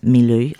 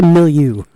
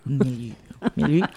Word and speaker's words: Milieu: Anyway, it is now my pleasure to welcome to Hush and Pandemonium members of local garage punk band Last Milieu. I hope I'm saying that right. – It's actually Milieu. – Milieu.